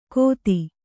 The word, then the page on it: ಗೋತಿ: Kannada